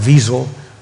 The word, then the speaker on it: Wiesel: Elie Wiesel, spelled with a W, pronounced Wiesel.